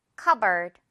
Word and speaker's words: cupboard: 찬장, 벽장 등 이거 cupboard 이렇게 발음이 되죠? P는 무시하세요. 듣고 따라해 주세요. cupboard.